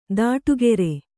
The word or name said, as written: ದಿಟ್ಟಗಾರ